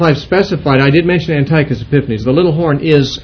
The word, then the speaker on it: Antiochus: Yes, it's like Antioch, like the city Antioch, A-N-T-I-O-C-H, and then with a U-S, Antiochus. Epiphanes is spelled E-P-I-P-H-A-N-E-S.